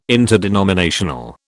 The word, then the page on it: interdenominational: British English